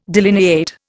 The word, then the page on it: delineate: Indian